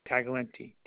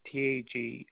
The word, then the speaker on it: Taglienti: Last name Taglienti. Taglienti.